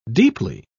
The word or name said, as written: deeply